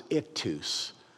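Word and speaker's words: Ichthys: That what I would say in English, I-X-O-Y-E, pronounced ictus, is Jesus.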